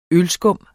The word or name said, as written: ølskum